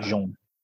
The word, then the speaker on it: Jean: I kept reading it as Gene, but it's, if you say that, that means it's like the French pronunciation, which is like Jean.